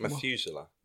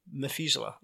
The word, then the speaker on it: Methuselah: What's that pronounced in English? – Methuselah. – Methuselah, okay.